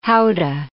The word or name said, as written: howdah